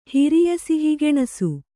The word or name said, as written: ಹಿರಿಯಸಿಹಿಗೆಣಸು